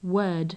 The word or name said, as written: word